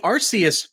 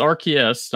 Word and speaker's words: Arceus: Last bit of the news for today is Pokemon The Arceus Chronicles, available for Netflix. – Yep, it's Arceus, not Arceus. They changed the pronunciation of the name.